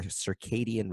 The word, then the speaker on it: circadian: Today, she's worked with hundreds of people to improve their sleep by strengthening their circadian rhythm.